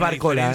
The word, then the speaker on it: Barcola: ¿Por qué decís Barcola y no Barcola? – Sí, porque creo que la pronunciación es así, nos lleva tilde. Ah. En este caso, sí, Mbappé, por ejemplo. Y bueno, y sí, es Barcola.